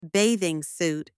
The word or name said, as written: bathing suit